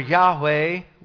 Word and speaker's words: Yahweh: The Lord, and that is the formal name of the Lord here, in Hebrew, Yod-Heh-Vav-Heh, or Yahweh, we pronounce it, right?